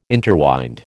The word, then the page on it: interwind: American English